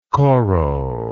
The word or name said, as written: koro